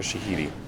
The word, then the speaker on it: Shahidi: Yara... Shadi? Shahidi? Shahidi? – Oh. – Yara Shahidi.